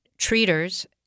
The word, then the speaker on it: traiteur: I look at the word and I want to pronounce it in French, something like traiteur, when you see it on signs in France, it means a caterer usually. But in southern Louisiana, it has its own meaning. And treaters, as I've heard it pronounced by people down around the bayou and in Cajun country, are healers.